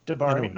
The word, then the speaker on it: D'Bari: I'm sure the editors at Marvel might have insisted on a change for the X Men's attitude toward the D'Bari. Am I saying that right? – D'Bari. No, that's right. That's right.